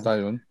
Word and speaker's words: Xian: You know, X-I-A-N, you know, we pronounce it Zion. Zion.